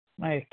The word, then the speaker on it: Maija: Maya K from New York, and it's spelled M-A-I-J-A.